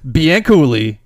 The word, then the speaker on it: Bianculli: Did I pronounce that right? Because I made that whole rhyme thing. Okay. – Yeah, Bianculli. You got it right.